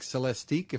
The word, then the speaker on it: Celestiq: So this is first going to be available in the very high-end Cadillac Celestiq, if I'm saying that right? – Yeah, you said it right. Correct.